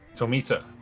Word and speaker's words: Tomita: So I am joined today by David Detweiler and Fumi, if I pronounce this wrong. I think it's Fumi Tomita? – Tomita.